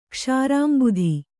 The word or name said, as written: ಕ್ಷೀರಾಂಬುಧಿ